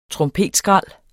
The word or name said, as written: trompetskrald